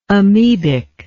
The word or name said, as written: amebic